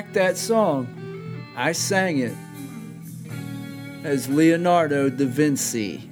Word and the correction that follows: Vinci: I did the C thing, okay? – Fancy. – But I realized that was wrong, and it's actually pronounced Chi. Da Vinci.